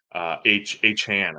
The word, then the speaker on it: Achane: Achane, I think is how you actually pronounce that.